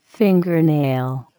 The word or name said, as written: fingernail